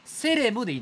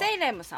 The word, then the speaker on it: セイレム: これはセレムでいいんですか読み方はセイレムさん日本の